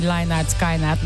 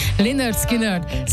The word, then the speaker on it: Lynyrd Skynyrd: Hát az a helyzet, hogy hát most már megtanultuk a zenekarnak kiejteni a nevét, mert még olyat is hallottam, hogy Lynyrd Skynyrd, nem. Lynyrd Skynyrd, Sweet Home Alabama.